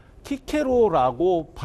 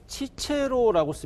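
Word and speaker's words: Cicero: 라틴어의 발음을 예를 들 때도 이 키케로라는 사람 역시도 키케로라고 발음한 것은 고전 발음이고 치체로라고 쓰면은 이거를 로마 발음 혹은 교회 발음이라고 합니다.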